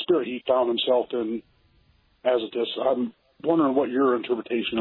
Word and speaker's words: Azotus: And then in the 40, it says Philip found himself in Azotus, if I'm saying that right. – Azotus, uh-huh.